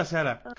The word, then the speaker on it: buonasera: Freunde, buona buonasera. Spricht man das so aus? Buona Buonasera. – Buonasera. – Perfekt ausgesprochen perfekt erklärt.